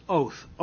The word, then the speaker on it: 'owth: And the word sign in Hebrew is pronounced oath, O-W-T-H.